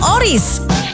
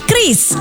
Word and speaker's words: QRIS: Ada yang menyebut QRIS, QRIS, atau ORIS. Kepala Grup Departemen Kebijakan Sistem Pembayaran DKSPBI, Fitria Irmi Triswati mengungkapkan, pengucapan yang benar adalah Kris.